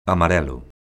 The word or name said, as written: amarelo